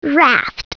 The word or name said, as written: raft